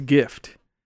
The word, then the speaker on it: GIF: At the very end, it spells gift. So you take the T out. What does it spell? Or how do you say it? GIF.